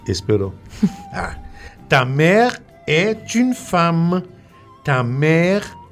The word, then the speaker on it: mère: Madre, en français, se dit mère. M-E, accent grave, R-E. Mère. M-E, accent grave, R-E. Se prononcia mère.